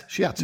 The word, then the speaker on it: shiatsu: It was shiatsu. Is that how it's pronounced? – Yes, shiatsu.